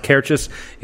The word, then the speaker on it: Cairdeas: C-A-I-R-D-E-A-S. – Yes, Kerdias is pronounced Kerchis.